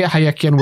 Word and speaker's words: Hayekian: yeah, I, I, would say Hayekian welfare state.